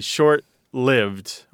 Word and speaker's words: short-lived: People think it should be short-lived. But that isn't the prevailing pronunciation. People at all levels of education and professional background and even linguistic authority unanimously say short-lived or long-lived is the prevailing pronunciation, even among the elite classes and all of the English-speaking world.